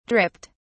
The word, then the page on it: dripped: British English